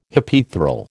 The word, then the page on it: Hypaethral: American English